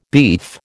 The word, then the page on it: beef: American English